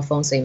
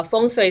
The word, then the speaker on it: feng shui: he'll add that r that shui. I can't even do it that shui sound, right?